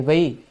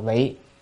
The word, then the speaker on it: vị: Chữ đản là chỉ, chữ này là chữ vị hay vi, ở đây phải đọc là vị.